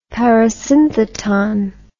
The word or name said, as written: parasyntheton